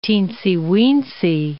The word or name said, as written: teensy-weensy